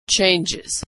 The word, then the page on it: changes: Iz